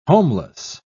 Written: homeless

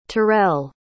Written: terrelle

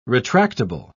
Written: retractable

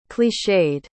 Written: clichéd

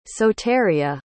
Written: soteria

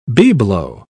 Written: bibelot

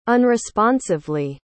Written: unresponsively

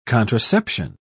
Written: contraception

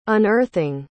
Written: unearthing

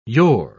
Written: yore

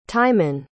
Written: Timon

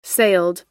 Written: sailed

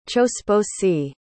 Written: chosposi

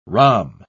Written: rhomb

